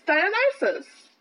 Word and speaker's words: Dionysus: It's Dionysus.